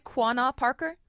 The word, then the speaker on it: Quanah Parker: Quanah Parker? – Yeah, you're saying just right, yes.